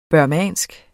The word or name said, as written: burmesisk